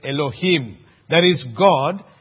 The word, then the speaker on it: Elohim: And the name of God that is used here is the Hebrew name that is called, we would pronounce it this way, Elohim.